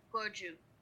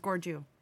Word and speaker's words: Gorju: So of course it'd be Korean American, not technically Korean, but so it is pronounced Gorju. – It is Gorju.